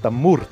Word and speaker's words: Tamourt: Et ça se prononce Tamourt.